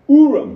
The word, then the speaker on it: Urim: they employed the use of Urim and Thummim, are the correct pronunciation, Urim mm-hmm. and Thummim.